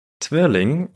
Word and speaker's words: Twirling: Twirling, und ja, das wird wirklich so ausgesprochen, das ist ein Sport, den wir hier in Deutschland gar nicht so wirklich kennen.